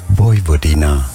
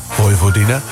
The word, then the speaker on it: Vojvodina: Ja, dit is een Vojvodina. Daar moet Ajax tegen. En ik heb even opgezocht hoe je het uitspreekt. – Vojvodina. – Vojvodina.